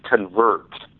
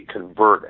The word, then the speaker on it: convert: Well, the word is convert. From Adam to Christ, and someone can pick up the title and pronounce it convert, like a noun, but it's actually convert as in a verb, as in it is God's job to convert the soul, or a person needs to convert to Christ.